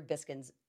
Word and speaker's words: Biskind: but Peter Biskind's, it's Biskind.